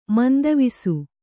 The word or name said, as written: ಮಂದವಿಸು